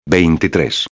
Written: 23

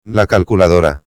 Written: la calculadora

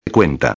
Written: cuenta

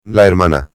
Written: la hermana

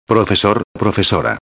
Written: profesor-a

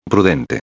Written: prudente

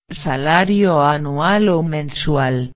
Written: salario anual o mensual

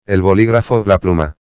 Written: el boligrafo, la pluma

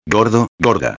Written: gordo/a

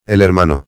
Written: el hermano

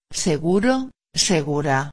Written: seguro-a

